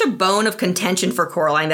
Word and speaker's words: Coraline: While movie Coraline only mildly corrects people when they mispronounce her name as Caroline, book Coraline gets much more incensed over the mispronunciations.